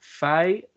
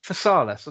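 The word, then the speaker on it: Physalis: Okay, so I've entered onto Google the pronunciation of it. And it says Phasalis. Phasalis, that's what I've been saying.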